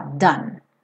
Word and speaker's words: done: Agora, o done, uma sílaba só, done. Esquece esse E final.